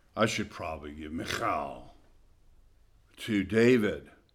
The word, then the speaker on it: Michal: This can be Michael, and as a matter of fact, it is the meaning of the name is the same as Michael, who is like God. The kind of Hebrew way to say is Michal, and I'm not good with the H.